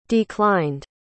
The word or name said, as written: declined